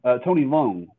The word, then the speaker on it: Tony Leung: When Tony Liu, I think is how you say his name, Tony Leung, and he's apparently like the most famous actor in Chinese film ever or something like that.